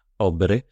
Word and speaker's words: Åberg: as it is actually correctly pronounced, Åberg. It is. There's a little circle in the A, so it's certainly not Åberg. And if anybody says Åberg, they're getting a firm hond. It's Åberg.